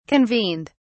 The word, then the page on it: convened: British English